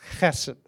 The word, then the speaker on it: chesed: But it's the Hebrew word chesed. And no, I'm not spitting, but it's chesed. That's just how it's said in Hebrew.